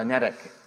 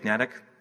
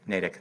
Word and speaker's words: Natick: Cycle setter, uh, Natick. Uh, I say it wrong. Natick, Natick, Natick. Thank you. I always get it wrong.